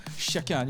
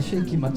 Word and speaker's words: Shake It: 네, 발음을 주의하셔야 됩니다. 샤키 아닙니다. 쉐이킷입니다. – 네, 그 섞는다고 쉐이킷 맞죠? – 네.